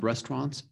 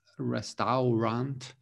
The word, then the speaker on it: restaurant: So a lot of people say restaurant, but actually here, that this right here is silent, okay? So it sounds like this. It sounds like restaurant.